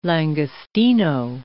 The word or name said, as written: langostino